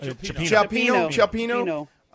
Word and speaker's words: Cioppino: All right, so let me ask you this: clam chowder, or one of those is it Cioppino? Is that how you say it? – Cioppino.